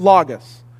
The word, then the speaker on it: logos: In Greek, if you were going to read it in Greek, you'd pronounce it logos.